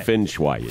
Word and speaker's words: Feng Shui: But Feng Shui or Fling Shui or what is it called again? Fling. That's what it is. Fling Shui? – No. – Shui? How do you say it? – Feng Shui.